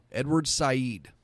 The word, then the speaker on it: Edward Said: Edward Said. It's spelt like said, S-A-I-D. Edward Said.